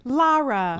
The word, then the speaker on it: Lara: Lara. – Lara. Why is that so hard for me? – It's like far or car.